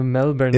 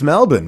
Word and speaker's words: Melbourne: It's Melbourne, mate. It's Melbourne. It's M-E-L-B-I-N, Melbourne. – Oh, I guess it is. – Well, that's how the locals would say it. Melbourne. I lived there. I lived in Melbourne, Australia for a couple of years, mate, so I got to say it like the locals.